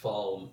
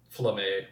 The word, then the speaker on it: Falme: It's pronounced Falm. – Who did they say Falme? – Flamme once.